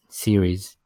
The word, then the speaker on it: Sires: It's SIRES series.